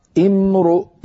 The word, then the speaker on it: امرؤ: إن امرؤ، لو وقفنا على إن وأردنا أن نبدأ، كيف نبدأ؟ نبدأ بهمزة مكسورة امرؤ.